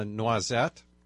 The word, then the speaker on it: noisette: And I mentioned that the rose was a noisette. – And how do you spell that? – N-O-I-S-E.